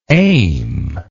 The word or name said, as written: aimfully